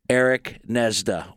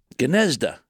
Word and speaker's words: Gnezda: The G is silent. – Then why have it? I've always wondered about that. – Well, why have it? – Well, you know, my dad, it's a Slovenian name meaning nest. And my dad grew up near an Indian reservation near Buffalo. And they always pronounced the G. They pronounced it Gnezda.